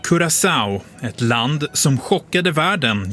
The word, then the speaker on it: Curaçao: Då vet ju du hur man uttalar landets namn. – Ja, man säger Curaçao.